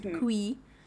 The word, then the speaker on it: Khui: Kui is spelled K-H-U-I, but pronounced Kui. They clear it quite early on.